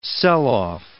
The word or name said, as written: sell-off